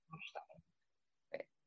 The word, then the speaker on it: Aisling: Today, I am here with Ashling Doolan. – It's pronounced Ashling.